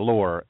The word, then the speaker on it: Loehr: Now, that's spelled different than you would think. L-O-E-H-R, Loehr, L-O-E-H-R.